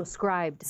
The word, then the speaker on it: Scribd: And then I don't know if you pronounce it Scribd. Is that how you say it? – Scribed. – Scribed. Okay, I've been saying it in my head completely wrong this whole time.